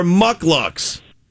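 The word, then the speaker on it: mukluk: Those are mucklucks. – Yeah. Mucklucks? Mudlucks. – They're mucklucks. That's a muckluck. M-U-C-K, yeah. Not mud, muck.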